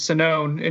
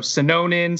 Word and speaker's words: Sinone: My last name's Sinone, and you should see how people butcher it, Coach. They go Sinonin, Sinoni. Like they add letters that aren't there.